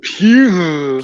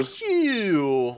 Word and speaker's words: Pugh: I mean, in general, Florence Pugh, or I think that's how you say it, Pugh? Correction there? – It's pronounced Pew. – Pew, like Pepe Le Pew.